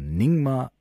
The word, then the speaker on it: Nyingma: Das ist ningmazentrum.de, ganz einfach, ningmazentrum.de, aber Ningma müsste ich euch nochmal buchstabieren.